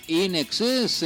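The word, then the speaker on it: INXS: Atención a este grupo porque lo escribimos como Inks con X y lo pronunciamos como In Excess en inglés, en exceso en castellano.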